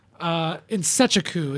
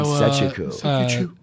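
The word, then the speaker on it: Nzechukwu: How do you say his last name? Is that Chukwa? – Insechiku is how. Insechiku. Anik was saying it, so I take Anik.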